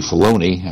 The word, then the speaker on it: Filoni: And it's actually Filoni. I keep mispronouncing his name.